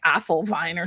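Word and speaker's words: Adolwyn: It's A-D-O-L-W-Y-N, and it's actually Welsh, so it's pronounced like Athelvine or something like that.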